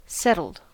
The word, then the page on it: settled: American English